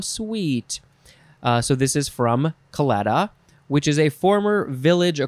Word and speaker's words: claddagh: Last word for this episode is claddagh, claddagh, claddagh, C-L-A-D-D-A-G-H, claddagh. I think that's the best way to pronounce it.